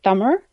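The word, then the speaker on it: Thummer (தமிழ்): The correct pronunciation is actually Thummer, but it's too hard because there's a sound at the end of the word that is not found in the English language. – Okay. – So instead, they made it an L and then that turned into Tamil.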